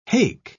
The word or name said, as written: hake